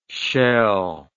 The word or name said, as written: scheel